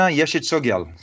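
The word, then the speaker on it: Yeshe Tsogyal: So these were places, if I recall correctly, Padmasambhava had shown Yeshi and after, or Yeshi Tsogyal. Do you know the pronunciation of that? Am I getting it right? – Yeah, no, Yeshi Tsogyal.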